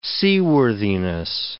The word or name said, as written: seaworthiness